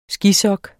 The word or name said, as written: skisok